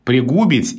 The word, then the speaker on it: пригубить: Я как-то запомнил для себя «пригубить» и «принудить». Вот два странных глагола, где очень хочется сказать с другим ударением, но ударение падает на «у», и вот мне было удобно их запомнить парой «пригубить» и «принудить».